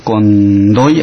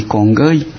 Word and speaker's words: Congoy: Para nosotros el Rey Condoy, así se en español así se pronuncia, pero allá en los Mijes es Rey Congoy.